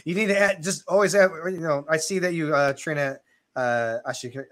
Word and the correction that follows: Arashikage: How do you pronounce what we're talking about, your hat? – Oh, the Arashikage. – There you go, that's how you get it.